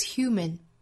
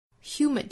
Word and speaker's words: human: Makes us human. Human 这个词第二个音节，这个 M-A-N 啊，读成刷 sound. Human 不是 human 啊，而是 human.